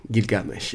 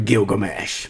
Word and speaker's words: Gilgamesh: Ja, ja, Gilgamesh is natuurlijk, dat klinkt wel logischer dan de Engelse Gilgamesh.